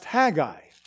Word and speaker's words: Haggai: So there's a passage in one of the last little prophets of Israel, Haggai, pronounced Haggai.